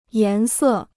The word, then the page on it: 颜色: yán sè